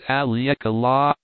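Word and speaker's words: Haleakala: As soon as I get back from Alicala, pronounced Aliekala,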